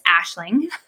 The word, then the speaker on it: Aisling: Aisling means vision in Irish. And actually, if you go to Ireland, they would yell at me. It's Aisling. But we said it wrong from the start.